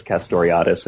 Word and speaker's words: Castoriadis: And here you're drawing on the work of the Greek scholar Cornelius Castoriadis, if I'm pronouncing it correctly. – Castoriadis, yeah.